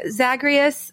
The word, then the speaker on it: Zagreus: I think the animation, the abilities, the character development, the voiceovers, the hunky Zagreus. Is that how you say his name? – Uh-huh.